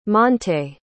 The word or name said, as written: montay